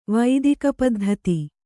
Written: ವೈದಿಕಪದ್ಧತಿ

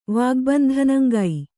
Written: ವಾಗ್ಬಂಧನಂಗೈ

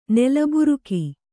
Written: ನೆಲಬೈರಿಗೆ

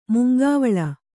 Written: ಮುಂಗಾವಲು

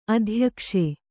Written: ಅತ್ಯಾಕಾಶ

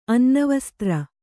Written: ಅನ್ನವಸ್ತ್ರ

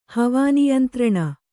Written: ಹವಾನಿಯಂತ್ರಣ

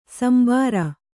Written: ಶಾಂಬರ

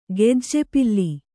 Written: ಗೆಜ್ಜೆಪಿಲ್ಲಿ

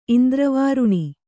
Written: ಇಂದ್ರವಾರುಣಿ

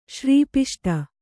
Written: ಶ್ರೀಪಿಷ್ಟ

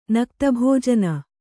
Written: ನಕ್ತಭೋಜನ

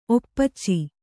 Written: ಒಪ್ಪಚ್ಚಿ

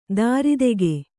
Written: ದಾರಿದೆಗೆ

